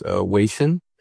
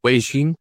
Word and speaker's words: Weixin: Oh, it's well, it's WeChat, but the website is, or the Chinese way to say it is Weixin. – Weixin.